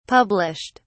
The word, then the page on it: published: British English